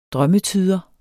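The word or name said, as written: drømmetyder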